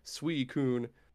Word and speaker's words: Suicune: Pokédex thing always had the pronunciation thing like a dictionary, and they pronounced it Suicune.